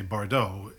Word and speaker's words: Bardot: And I say, Bardot, like Bridget Bardot.